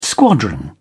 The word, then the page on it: squadron: British English